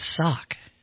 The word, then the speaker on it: Sauk: How do you say it? What's your name? – Sauk. – I think it's Sauk.